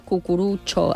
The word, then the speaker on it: cucurutxo: Cucurutxo té moltes us, no? – Cucurutxo... No, atenció, que nosaltres ho pronunciem en oriental, però acaba amb O, eh? Cucurutxo, escrit amb TXO.